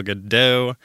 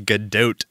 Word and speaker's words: Gadot: Even I thought it was Gal Gadot. Turns out it is Gadot, as in it rhymes with tote.